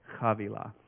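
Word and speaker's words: Havilah: It's spelled H-A-V-I-L-L-A or V-I-L-A, something like that, but it's said Chavila.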